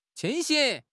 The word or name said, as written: 清醒